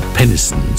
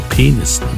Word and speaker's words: Peniston: Peniston mit Finally. Muss man schnell aussprechen den Namen Peniston, sonst klingt es doof, ja, sonst heißt es ja Peniston.